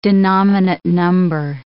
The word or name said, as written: denominate number